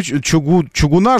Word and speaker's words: чугуна: Я хочу ударение посмотреть, потому что все время хочется правильно как-то. Ударение, что такое? Да елки-палки, невозможно найти. Ударение в слове. Вот. Вы тоже не знаете, как правильно. Правильно. Чугуна.